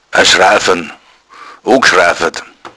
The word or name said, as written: schrafan